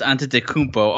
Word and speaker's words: Antetokounmpo: What I mean by that is I'm going to Giannis Antetokounmpo.